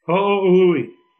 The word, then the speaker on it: Ho'ohuli: It's H-O apostrophe O-H-U-L-I. – Ho'ohuli. – Wyndon Ho'ohuli.